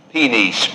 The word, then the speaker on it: penes: The first word, you don't need to remember the Greek, but remember the thought behind it, is the word penes, P-E-N-E-S, with a long E.